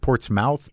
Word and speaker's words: Portsmouth: So now, okay, let's get into the, because I don't know Portsmouth. Is it Portsmouth? – Portsmouth. Yep. Yep. – It's spelled Portsmouth, though, isn't it? – Yes, it is, yeah.